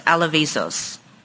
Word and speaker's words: Alevizos: I believe his last name is pronounced Alavisos. Hmm. That's my best pronunciation. It's spelled A L E V I Z O S.